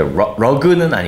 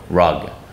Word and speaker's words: rug: 우리가 러그라고 외래어로 많이 쓰잖아요. 이게 러그는 아니고 rug 이렇게 발음하셔야 됩니다.